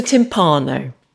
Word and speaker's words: timpano: Timpano is how we pronounce it. You're pronouncing it correctly. But that's how my family pronounce it.